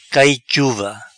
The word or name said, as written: Cai Chuva